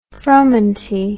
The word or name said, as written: fromenty